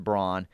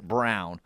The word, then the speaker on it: Braun: He would also like everyone to know this texter's last name is Braun, pronounced Braun, and it makes him mad, and he hates the Kansas basketball player the way he pronounces it, Brown.